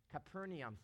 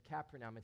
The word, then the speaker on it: Capernaum: Capernaum, some people say it, but Capernaum is the way they pronounced it over there.